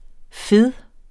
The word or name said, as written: fed